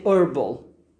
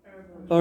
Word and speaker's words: herbal: You can also say herbal. Herbal, herbal yes. With H. In American English, the H is silent. In British English, it's herbal.